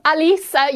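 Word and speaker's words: Halys: You've completely shamed me there with your pronunciation. We're actually talking about the same person, Quentin Halas and Quentin Alice. You've absolutely nailed it much better than me.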